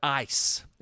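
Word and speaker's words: eis (εἰς): That word for is the Greek word eis. It's spelled E-I-S.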